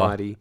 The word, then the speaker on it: Naudi: N-A-U-D-I is his first name. Oh, Naughty. – I thought it was... I wasn't thinking that. – And then Aguilar is A-G-U-I-L-A-R.